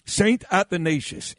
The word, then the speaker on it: Athanasius: Is that how you say it? Ignatius? Do you remember? – Spell it, I'll tell you. – A-T-H-A-N-A-S-I-U-S. – Athanasius. – There you go. – That's what I said. – St. Athanasius. You're exactly right, yep.